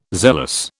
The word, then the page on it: zealous: British English